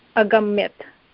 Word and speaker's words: अगम्यत: न अगम्यत् इति न तत्र हलन्तशब्दः अस्ति वा? – आम् अगम्यत. – हा अगम्यत इति अवश्यं वक्तव्यमस्ति.